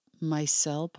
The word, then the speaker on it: micelle: so when one clicks on the science there, you get to see the comparison between nanoparticles, micelle particles. Am I saying that right? Mm-hmm. Yeah.